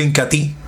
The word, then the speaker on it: Incatea: Se escribe Incatea, pero se pronuncia Incati.